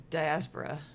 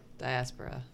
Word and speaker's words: diaspora: Real quick, mostly because I feel like you're going to end up saying that a lot, you were not pronouncing diaspora correctly. And because I'm too tired of... – Diaspora. – Yeah. – Diaspora? – Diaspora.